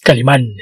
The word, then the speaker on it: Calimán: Es Calimán, Esteban, lleva acento en la A.